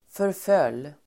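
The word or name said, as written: förföll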